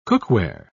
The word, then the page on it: cookware: British English